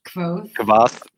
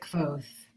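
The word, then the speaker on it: Kvothe: Kvoth. I always pronounced it Kvoth. – Kvoth. – Kvoth, like a soft V.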